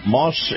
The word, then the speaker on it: Maus: Now, Moss is spelled M-A-U-S, and if you love the feel of a high-end SUV, you're going to love Moss Acura.